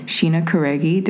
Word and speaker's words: Sheena Karegi: Have a really great treat for you today because I have a good friend, Sheena Karegi.